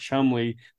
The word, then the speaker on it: Cholmondeley: So there's like guys like we keep saying Charles, but his last name is Chumley, but it's pronounced Chumley, but it's spelled.